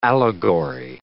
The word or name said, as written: allegory